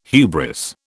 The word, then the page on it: hubris: British English